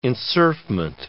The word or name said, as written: enserfment